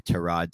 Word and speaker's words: Tyrod: The first is Tyrod Taylor or Tyrod Taylor, as it's actually pronounced, but he does he does allow people to, to call him Tyrod.